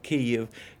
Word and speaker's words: Kyiv: The truth of the matter is that Kyiv should be very easy to pronounce for Americans who speak English, because number one, the word consists of two syllables. The first syllable has the vowel e, which is positionally almost identical to e in such words as pit, lid, tid.